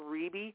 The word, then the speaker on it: Riebe: Just look me up under my name, Neil Riebe. My name is spelled N-E-I-L, and then last name, R-I-E-B as in boy, E.